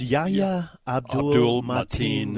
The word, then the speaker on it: Yahya Abdul-Mateen: Yeah, it's Yahya Abdul-Mateen II.